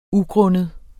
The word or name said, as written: ugrundet